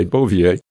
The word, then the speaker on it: Bouvier: I forget his first name, but his last name was, is it Bouvier? Is that the way you pronounce it? – Yep. – Okay, Bouvier. And they had a 9-year-old daughter.